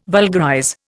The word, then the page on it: vulgarize: Indian